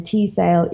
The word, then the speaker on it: Teesdale: Amy is spelled A-I-M-E-E, C for Charlene, and then Teasdale is T-E-E-S-D-A-L-E.